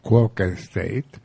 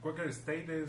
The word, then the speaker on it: Quaker State: ¿Saben ustedes, se han fijado en esa expresión Quaker State? Que Quaker State, que una de las palabras se pronuncia en español y la otra en inglés. Decimos Quaker en español y State en inglés.